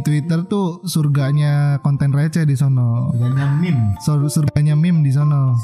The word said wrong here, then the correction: meme: Bacanya meme ya.